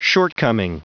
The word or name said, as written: shortcoming